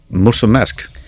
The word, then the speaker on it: Mulțumesc: How do you say thank you in Romanian? – Mulțumesc.